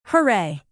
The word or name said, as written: hooray